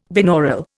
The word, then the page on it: binaural: Indian